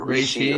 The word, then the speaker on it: reishi: Like today I had cordyceps, dual extracted cordyceps, or maybe I have lion's mane or reishi or chaga.